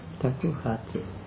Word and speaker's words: takuhatsu: When you used to do takahatsu roshi, takahatsu, how do you say that? – Takuhatsu. – Takuhatsu, it's long year, takuhatsu.